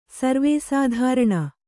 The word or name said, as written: ಸರ್ವೇಸಾಧಾರಣ